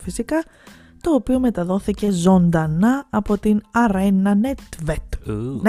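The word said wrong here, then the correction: Næstved: Nestvet, όχι συγγνώμη, να το πω σωστά. Arena Nestvet.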